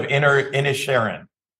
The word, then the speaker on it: Inisherin: It's pronounced Banshees of Inisherin.